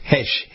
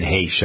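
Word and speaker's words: Heche: Ann Hesh, I believe that's right, isn't it? – Yeah, Hesh.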